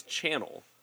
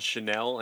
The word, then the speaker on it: Channel: That as somebody whose last name is Channel, but it's kind of spelled like it could be called Chanel, and having people go, I don't know, I prefer Chanel.